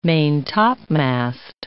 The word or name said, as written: main-topmast